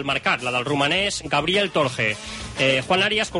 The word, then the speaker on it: Torje: La pronunciación correcta. Luego supongo que el propio jugador nos lo explicará. pero sí somos un poco precisos en cuanto a la pronunciación en rumano, algo así como torche.